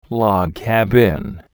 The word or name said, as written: log cabin